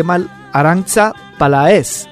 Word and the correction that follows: Peláez: Palaez, perdón. Peláez, perdón.